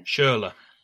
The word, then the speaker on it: Schürrle: Is that how you pronounce it? – Schürrle. – Schürrle, thank you.